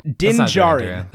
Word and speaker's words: Djarin: Din Djarin. – That's the Mando's name? – D-I-N-D-J-A-R-I-N. – The D is silent? – Yeah.